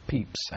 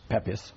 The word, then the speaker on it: Pepys: He pronounced it as Peeps, actually. I mean, when I say actually, it's spelt that way, but they pronounced it Peeps, Pepys.